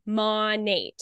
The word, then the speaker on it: Monat: It is from the two words modern and nature. So it's actually pronounced Monate.